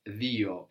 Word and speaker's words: δύο: But this is pronounced dio in Greek.